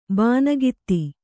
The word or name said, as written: ಬೋನಗಿತ್ತಿ